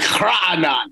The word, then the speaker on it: Raanan: It's like Raanan, because like only Israelis or Arabs really can pronounce my name correctly.